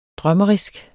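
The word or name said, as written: drømmeriske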